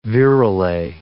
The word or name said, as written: virelay